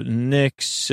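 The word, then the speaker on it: Pnyx: The Knicks, which has a silent P apparently, where the Athenians would talk, democracy started.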